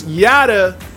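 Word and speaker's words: Yadah: It's Yada, like praise, the King.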